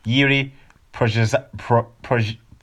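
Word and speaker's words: Jiří Procházka: No. Let's do the man some justice. Yuri Prochazka. There we go.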